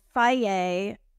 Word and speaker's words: Fage: But like if it's a package of yogurt, you know, Faye, F-A-G-E, also like constantly mispronounced. – F-A-G, that's a yogurt? F-A-G-E? – F-A-G-E, yeah.